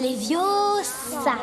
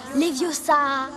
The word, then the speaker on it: Leviosa: C'est Leviosa. Et pas Leviosa.